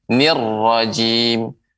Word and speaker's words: الرجيم: Pengucapan ro, jadi dia tidak boleh terlalu banyak getarannya. Nir rajim. Nah, itu kurang tepat, Nek. Pengucapan yang tepat أعوذ بالله من الشيطان الرجيم nirrojim ya getarannya jangan terlalu banyak jangan nirrojim tapi nirrojim tebal hurufnya coba diulangi nek ya.